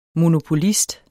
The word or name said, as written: monopolist